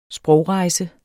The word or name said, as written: sprogrejse